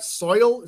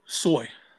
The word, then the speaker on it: soy: Soil? Is that how you say it? – Soy.